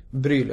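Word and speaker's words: Brühl: Bryl, ja, precis.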